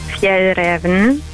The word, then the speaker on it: Fjällräven: Und es wird richtig ausgesprochen, Fjällräven. – Fjällräven. – Also F werden im Deutschen als S ausgesprochen.